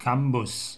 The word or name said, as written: Cambus